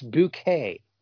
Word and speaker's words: Bucket: Makes me think of the show Keeping Up Appearances, because the main character's last name is spelled Bucket, Bucket. But you always say it's Bouquet.